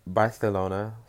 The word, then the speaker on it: Barcelona: Actually, Barcelona, sorry. that's 'cause that's how they say it over there, 'cause they it's a whole thing.